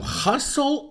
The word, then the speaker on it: hustle: É a expressão hustle and bustle. Interessante que a letra T não é pronunciada em nenhuma das palavras, né?